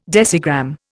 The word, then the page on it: decigram: Indian